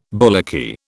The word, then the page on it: bullocky: British English